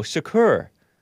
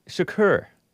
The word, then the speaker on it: Chokr: So his last name is pronounced, according to these people, Choker. Choker. Shakur, like Tupac Shakur, S-H-A-Q-A-K-U-R, even though it's spelled C-H-O-K-R. I was pronouncing it choker.